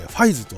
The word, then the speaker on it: 555: 555 と書いてファイズと呼ぶんですね、こちらのオープニングジャスティファイズというね、最後のギリシャ文字のファイがね書かれてるんですけど、あの仮面ライダーファイズ自体のね顔もあのそのギリシャ文字のねあのファイの字をモチーフにしていると。